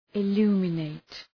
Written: illuminate

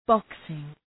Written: boxing

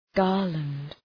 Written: garland

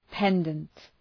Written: pendant

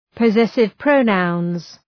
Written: possessive pronouns

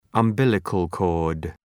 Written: umbilical cord

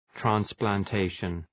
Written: transplantation

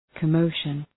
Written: commotion

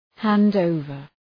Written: hand over

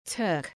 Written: Turk

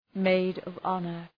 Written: maid of honor

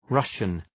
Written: Russian

